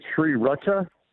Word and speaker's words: sriracha: The other one that's kind of a pet peeve that you hear a lot in Rochester is sriracha, and that's with its S-R-I, but people pronounce it as if it's S-I-R, and that needs to be straightened out as soon as possible. – Sriracha, sriracha.